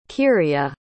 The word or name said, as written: kyria